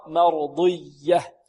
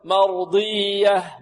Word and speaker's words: مرضية: والياء هنا مشدده فيها نبر، مرضيه، لا نقول مرضيه مرضيه ضيه، لا هذا ليس ادغاما، هذا اظهار، مرضيه، لا لابد من النبر والضغط والتشديد. راضيه مرضيه مرضيه، ولا نقول مرضيه ضجه، هكذا لا، هذه شده خطأ، وإنما ننطق الياء مشددة فيها ضغط وكذلك ماذا؟